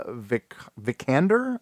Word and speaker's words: Vikander: But apparently Lara Croft is going to be played by Alicia Vikander.